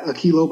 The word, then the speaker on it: Uhkeylow: Uh, on Instagram, I guess you can follow me there too at Akilo, but it's a different spelling. U H K E Y L O W. It's like telling you guys how to pronounce my name.